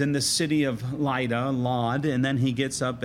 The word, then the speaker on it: Lod: The city of Lydda is also the city of Lod. L-O-D is the modern day spelling and pronunciation of it.